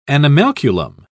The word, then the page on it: animalculum: British English